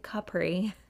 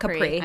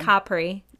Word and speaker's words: Capri: The right way to say Capri. Not Capri. – I know. – Capri.